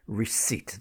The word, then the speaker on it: receipt: And then the word receipt. This is one that always causes problems with pronunciation. You go into the shop, you buy a product, you get your receipt. R-E-C-E-I-P-T, but it's silent P, so it sounds like R-E-C-E-I-T, receipt. Yeah, don't sound the P, receipt, receipt.